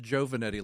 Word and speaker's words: Giovanetti: No, it, it's pronounced Giovanetti, like J O E.